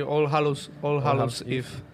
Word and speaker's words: All Hallows Eve: Sí, All Hallows Eve. – Mi profesora no estaría orgullosa de esta pronunciación. – No, está bien, la ha hecho bien.